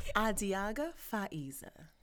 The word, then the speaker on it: Adiagha Faizah: Adiagha Faizah.